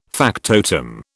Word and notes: factotum: British English

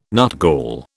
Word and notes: nutgall: British English

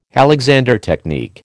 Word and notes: Alexander technique: American English

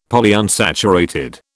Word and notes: Polyunsaturated: British English

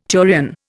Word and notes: turion: Indian

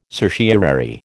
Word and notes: certiorari: American English

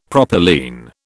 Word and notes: propylene: British English

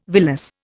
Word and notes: villus: Indian

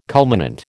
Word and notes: culminant: American English

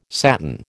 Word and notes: satin: American English

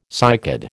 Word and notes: cycad: American English

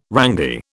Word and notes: rangy: British English

